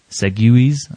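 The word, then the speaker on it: segue: Segues, I believe I believe that's the proper pronunciation, segues, because yep.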